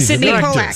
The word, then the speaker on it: Sydney Pollack: He started, his first job was at Mirage Studios, and he was an assistant for Cindy Pollack. Am I pronouncing Sydney Pollack? Right? – Sydney Pollack. Sydney, the director.